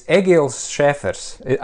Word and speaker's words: Egils Scheffers: Yes, it's Egils Scheffers. I have a German last name. It's sort of a Scandinavian first name with a Latvian ending, but a pure German last name, Scheffer.